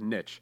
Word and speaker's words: niche: It's ironic, or not ironic, it's coincidental that Switch rhymes with niche, because this game is a niche system.